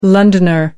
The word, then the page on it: londoner: American English